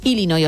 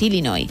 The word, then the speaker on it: Illinois: Pero es Illinois, o sea, si tú lo pronuncias bien y tienes buena dicción, es Illinois. – Illinois. – Luego, si lo pronuncias rápido, según el acento, como decía Beto también, pues te sale una medio E, pero nunca un Illinois.